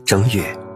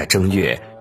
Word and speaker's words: 正月: 就把改正后的一月称为正月。此外，古代的帝王大都在一月接受文武百官的朝拜，为了表示庄重独尊，便将一月改为了正月。到了秦代，因秦始皇出生在正月，取名嬴政，为了避他的名讳，强行规定把“ 正” 字读作“ 征”。